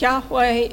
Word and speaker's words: Yahweh: The best evidence that we have suggests that Yahweh is the correct pronunciation.